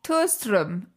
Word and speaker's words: Thåström: T- t- Thåström.